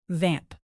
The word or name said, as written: vamp